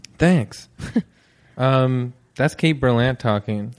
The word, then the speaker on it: Berlant: Thank you for saying it right, because people will say, I feel like my name is very, it's like phonetic, Berlant. It's pretty clear. People will say Berlant.